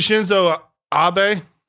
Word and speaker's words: Shinzo Abe: Yeah, uh, we we got a story that we didn't cover the past few weeks Assassination Shinzo Abe. Is that how you say it? Yeah.